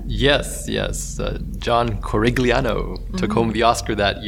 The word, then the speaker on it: Corigliano: So the composer, John Corigliano.